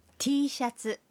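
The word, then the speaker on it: টি শার্ট (Tシャツ): টি শার্ট শব্দটির জাপানি উচ্চারণ হল টি শার্ট,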